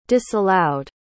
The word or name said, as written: disallowed